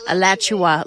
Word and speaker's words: Alachua: Alachua? Is that how you say it? – Alachua, like Joshua, but Alachua. Alachua.